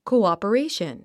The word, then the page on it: cooperation: kouὰpəréiʃən; コゥオペレイション